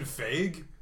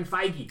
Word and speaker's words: Feige: It's not Kevin Feige? – No, it's Kevin Feige.